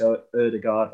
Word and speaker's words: Odegaard: Odegaard, that's the way you pronounce it, got when he sort of drifted in between the lines.